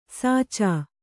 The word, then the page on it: ಸಾಚ: Kannada